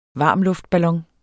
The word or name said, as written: varmluftballon